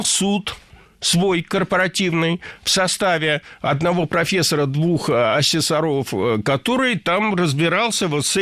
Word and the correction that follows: ассессоров: Ассессоров – неверно. Правильно – ассессоров. Существительное «ассессор» имеет неподвижное ударение на втором слоге. Ну а раз оно неподвижное, значит, мы его не двигаем при изменении слова. Ассессоров – ассессорами – ассессором.